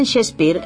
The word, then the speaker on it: Shakespeare: Con permiso del Mango de Lepanto, que celebra la misma efeméride, 2016 es también el año de Shakespeare. – William Shakespeare, los entendidos pronuncian Shakespeare, nació en Stratford-upon-Avon, una pequeña población situada en el condado de Warwickshire, a unos 160 kilómetros al norte de Londres.